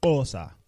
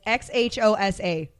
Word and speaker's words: Xhosa: Xhosa. – Okay, it's Xhosa.